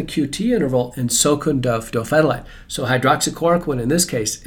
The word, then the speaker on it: dofetilide: Dofetilide. – Dofetilide.